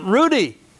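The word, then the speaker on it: ruddy: When the Philistine looked and saw David, he disdained him, for he was but a youth and Rudy, with a handsome appearance. Already. Oh, okay. Two D's. Two D's. That's right. It cancels the long U.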